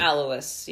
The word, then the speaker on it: Alois: So he had a half-brother, Alois Hitler Jr. We think we're pronouncing that correctly. – Alois, yeah.